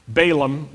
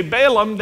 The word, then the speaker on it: Balaam: And in Israel, if you say Balaam, they don't know what you're talking about. So we'll use both pronunciations, the Southern American and the Israeli Hebrew, for this person. We learn that Balaam is held accountable for his plan to bring harm to Israel.